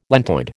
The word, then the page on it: lentoid: American English